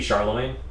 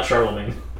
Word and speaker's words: Chalamet: Did you say Timothy Chalamet? – Did I say his name wrong? – His name is not Charlemagne. – Charlemagne? What is it? – Chalamet. – Chalamet.